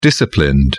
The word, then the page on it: disciplined: British English